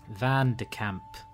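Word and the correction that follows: Van de Kaap: It's actually Van de Kapp. – So oh, what? Oh. – K-double-A-P. K-double-A-P.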